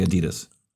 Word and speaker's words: Adidas: Only Americans say Adidas. – Well, I'm saying Adidas.